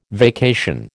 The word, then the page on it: vacation: American English